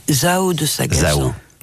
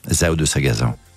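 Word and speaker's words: Zaho de Sagazan: De Sagazan? – Zao de Sagazan. – Zao de Sagazan.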